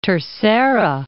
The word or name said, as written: Terceira